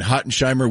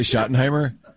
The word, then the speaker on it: Schottenheimer: Nate Thomas had gotten a lot of praise from Brian Hottenheimer.